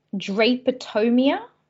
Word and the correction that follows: drapetomania: Ah, drapetomania. – Excuse me, yes.